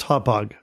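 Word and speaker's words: Hauppauge: By the way, everybody, it's pronounced Hotpog.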